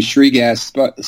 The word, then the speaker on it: Shrekgast: Well, I guess to start out with the easiest one, my last name being Shrekgast, spelt with S-H-R-E-K, and being that trading desk people aren't necessarily known as being easy on people, I couldn't get away from that. So I am forever known as Shrek, which is a lot easier to pronounce than Shrekgast with all its consonants together.